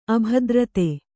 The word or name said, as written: ಅಭದ್ರತೆ